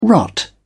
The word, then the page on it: rot: British English